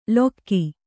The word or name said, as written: ಲೊಕ್ಕಿ